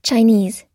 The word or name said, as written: chinese